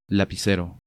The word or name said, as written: Lapicero